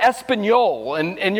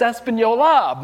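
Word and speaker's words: Española: And then it's right before you get to Espanol, and you're like, it's not, there's an A on the end of that. It's Espanola, not Espanol, but we're a country, so we don't know.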